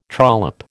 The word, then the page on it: trollop: American English